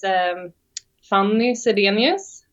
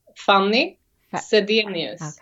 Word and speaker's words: Fanny Zedenius: That's how you pronounce it in Swedish. – Oh, wait. Say it again. Pronounce it slowly for me. – Fanny Zedenius. – Okay. – Yeah. It's more like funny. – Uh-huh. – But yeah. So it's not Fanny.